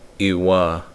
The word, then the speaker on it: iwa: The first word, hiwa, means his, her, or its heart. With the glottal stop, iwa means my heart. Another important note, that stress is always on the last syllable of the word in Maricopa.